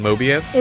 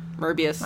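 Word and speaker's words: Möbius: Pronounced Mo in the original word. – Oh. Well, then. – It's pronounced more like Mer in the original word, isn't it? Mobius. – Mobius.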